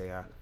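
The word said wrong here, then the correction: Saleh: I can't really pronounce his name. That's why I was like. – Sala. – Yeah, there you go.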